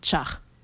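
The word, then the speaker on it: ĉar: Et donc, ce son se prononce tch, donc char.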